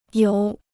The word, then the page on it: 游: yóu